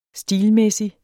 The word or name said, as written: stilmæssig